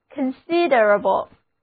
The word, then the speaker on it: considerable: 重音放在第二个音节上，单词就读作 considerable。